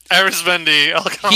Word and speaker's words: Arizmendi Alcantara: He used to get shit for the way he pronounced Arizmendi Alcantara's name. And he's like, who I asked? I asked him. – Arizmendi Alcantara. – He told me how to say his name. So stop complaining because I'm saying it the way he told me to say it.